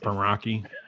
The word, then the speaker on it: Meraki: So I know I had somebody from Meraki asking if we would flatter Meraki, and if I believe it's pronounced Meraki.